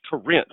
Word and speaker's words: Corinth: but he would always correct me. Corinth. It's Corinth, man. Say it right.